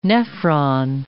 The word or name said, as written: nephron